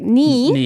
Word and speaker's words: 네: So like I said, the 네 should be pronounced like 니.